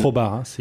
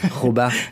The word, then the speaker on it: Khobar: Khobar. Le match de première ligue saoudienne... – Je crois qu'on dit Khobar. – Khobar.